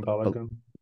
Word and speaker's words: Balogun: Florian Balogun.